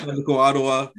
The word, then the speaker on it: Atlético: Atlético Ottawa.